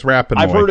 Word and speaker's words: Rapinoe: Megan, and I think you pronounce this last name Rapinoe. – It's Rapinoe.